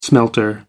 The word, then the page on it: smelter: American English